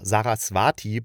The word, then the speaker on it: Saraswati: Und das geht um eine Düsseldorfer Professorin für postkoloniale Theorie, die sich nach der hinduistischen Göttin Saravas Saras oh, Saraswati benannt und fälschlich als Inderin ausgegeben hat.